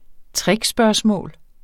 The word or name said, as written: trickspørgsmål